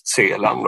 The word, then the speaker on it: Celan: han heter ju då i Frankrike Paul Celan, men han var ju tysk från början och han, hans uppfattning var att i tyskspråkiga länder eller, eller germanska länder så ska det uttalas Celan då, istället.